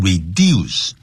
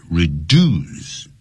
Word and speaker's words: reduce: 尽管如此他这并没有减少这个 lesson, 在日本就得 reduce, R-E-D-U-C-E, 不要念成 reduce, 在 in American English, that word is pronounced as, 怎么样呢发音成所谓的 reduce, reduce, 不是 reduce.